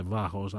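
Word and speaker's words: Vahos: Ελμπούο λοιπόν, γράφεται με H, δεν προφέρεται το H, όπως και στο επίθετο της Βάος, που είναι Βάος, αν θέλετε να τους αναζητήσετε.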